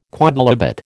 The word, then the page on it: quodlibet: American English